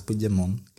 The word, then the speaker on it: Puigdemont: Mon. Puig, Puig, Puig de Mon.